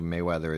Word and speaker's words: Mayweather: Is it Mayweather or Merryweather? – Mayweather. Big boxing fan, are you? – No, not huge, man.